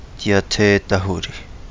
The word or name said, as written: Di Ate Dahuli